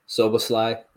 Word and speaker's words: Szoboszlai: Dominic Szoboszlai. – Close enough. Szoboszlai is how I've heard it.